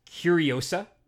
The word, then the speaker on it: Curiosa: One is Curiosa, which is a period piece erotic drama. – It's not the Mad Max spinoff about Furiosa. – It just rhymes. – It rhymes with Furiosa.